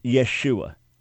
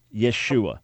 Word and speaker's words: Yeshua: The proper pronunciation of the Lord's name as he would have been known in Hebrew is Yeshua. Yeshua.